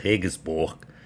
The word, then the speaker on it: Regensburg: Speaking of German beer, there's a place in Germany called Regensburger I don't know.